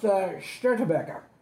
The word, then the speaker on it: Störtebeker: and it's pronounced Störtebeker.